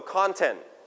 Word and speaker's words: content: So this is now, and you have to remember where the stress is. Now, content or content. Stress on the first or second syllable. So we pronounce con with high note. Now, con, everybody repeat after me, content.